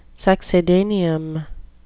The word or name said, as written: succedaneum